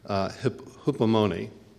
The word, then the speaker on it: hypomone: And that's the Greek word hypomone. Hypomone is the way that's pronounced.